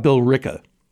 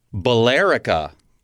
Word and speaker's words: Billerica: B-I-L-L-E-R-I-C-A. Continue reading. – When I first saw the word, I thought Billerica. What a melodious sounding name for a town. – It's true. – Imagine my surprise when my friend told me the locals say Billerica.